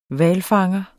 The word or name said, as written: hvalfanger